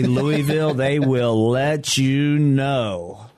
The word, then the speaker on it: Louisville: Louisville. – There we go. – They don't pronounce it as Louis. It's Louis, and it's Louisville.